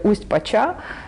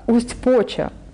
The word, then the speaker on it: Усть-Поча: Вот обратите внимание, что я там даже неправильно ставлю ударение в названии деревни. Я говорю «Усть-Поча», хотя она «Усть-Поча». То есть до такой степени я была тогда еще не в теме.